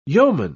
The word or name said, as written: yeoman